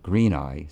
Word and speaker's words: greenei: Christina pronounced it greenei, so that's a good way you can remember how to pronounce it.